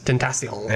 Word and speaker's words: Tentacion: Is that, how do you say his name? – Triple X Tentacion? – Testacion? – Tentacion. – Yeah. Yeah.